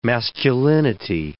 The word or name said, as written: masculinity